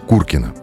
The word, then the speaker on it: Куркино: Итак, речь о районе Куркина – Ударение на первый слог.